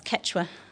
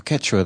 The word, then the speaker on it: Quechua: That's Quechua. – Oh, Quechua, that's not so bad.